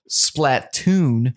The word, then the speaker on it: Splatoon: Which is crazy because I just saw Splatoon.